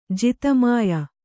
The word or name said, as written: ಜಿತಮಾಯ